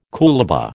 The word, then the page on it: coolabah: American English